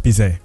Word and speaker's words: Bizet: It's pronounced Bizet.